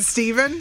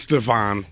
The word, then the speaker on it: Stevan: Is it Stephen? Stefan? – It's Stefan. – Stefan. Stefan. Well, it's spelled S-T-E-V-A-N, and you lost me at athlete.